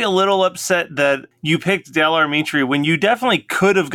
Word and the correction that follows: Del Amitri: First of all, you've been mispronouncing the name of this band since day one. – Oh yeah, Del Amitri.